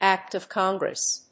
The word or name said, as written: act of congress